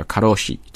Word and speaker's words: karoshi: And even has its own word，甚至呢这种在因为工作的方式过度的劳累死掉的方式还有专门的一个词叫 karoshi，其实跟咱们中国的发音就非常像了，就是过劳死，太辛苦了。